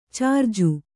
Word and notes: ಛಾರ್ಜು: chārju

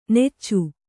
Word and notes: ನಿಚ್ಚ: nicca

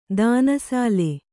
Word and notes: ದಾನಸಾಲೆ: dāna sāle

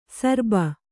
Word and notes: ಶರಭ: Kannada

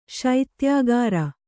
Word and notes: ಶೈತ್ಯಕಾರಿ: śaityakāri

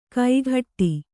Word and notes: ಕೈಗಟ್ಟಿ: kaigaṭṭi

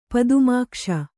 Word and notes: ಪದುಮಾಕ್ಷ: padumākṣa

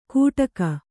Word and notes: ಕುಟಕ: kannada

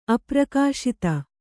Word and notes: ಅಪ್ರಕಾಶಿತ: kannada

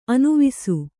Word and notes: ಅನಿವಾಸಿ: kannada

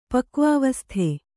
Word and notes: ಪಕ್ವಾವಸ್ಥೆ: kannada

